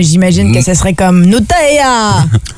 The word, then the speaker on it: Nutella: Nutella. – Nutella. – Nutella. – Nutella. – Nutella. Faut mettre l'accent sur le T. – Ouais. – Nutella.